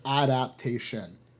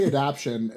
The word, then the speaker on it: adaptation: There's a whole other syllable in there. It's adaptation. – Adaptation? – Mm-hmm. It's A-D-A-P-T-A-T-I-O-N in the Queens. But people do say adaption.